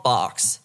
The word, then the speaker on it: Barks: So this all came about, extravagant amount of lore, because Carl Barks was this comic book creator. He had been creating... – Carl Barks? – Carl Marx? – Righto. – Okay, it rhymes, but it's C-A-R-L-B-A-R-K-S.